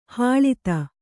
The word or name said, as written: ಹಳತು